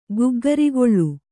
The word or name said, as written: ಗಗ್ಗರಿಗೋಲು